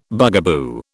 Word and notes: bugaboo: British English

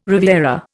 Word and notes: riviera: Indian

